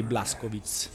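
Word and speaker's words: Blazkowicz: BJ Blaskowitz l'ho detto con la pronuncia eh, polacca.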